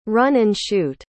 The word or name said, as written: run-and-shoot